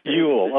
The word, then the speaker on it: Ewell: E-W-E-L-L, is it? – I think he pronounced his name Ewell, though. – Ewell.